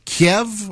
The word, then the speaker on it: Kiev: Is it Kiev or Kiev? – Kiev? Kiev? – What have we decided there? – I heard that in Russian they say Kiev, but in Ukrainian it's pronounced Kiev.